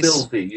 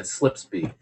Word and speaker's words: Spilsby: Slipsby in the United Kingdom. – Apologies to all our British fans for butchering that. – Yeah. – Spilsby? – Yeah. What did I say? – Spilsby. You said Slipsby.